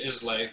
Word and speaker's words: Islay: And as you had stated, Islay, which I would have said it the same way, is actually pronounced Isla. – Isla. Oh, good to know. I had a feeling that S might have been silent.